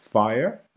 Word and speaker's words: FHIR: And the other one that we talk about is pronounced FHIR, which stands for Fast Healthcare Interoperability Resources. So it's actually FHIR.